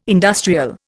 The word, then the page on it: industrial: Indian